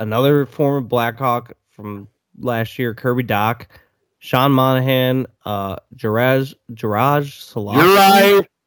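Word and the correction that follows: Juraj: Uri Slavkovsky.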